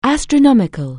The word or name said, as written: astronomic(al)